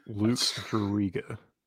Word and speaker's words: Jurita: I don't really know him as a player, but I know his name is not pronounced like you just pronounced it. – Luke Jurita. – That's better. Jurita. You're getting there. As opposed to Juke Jurita.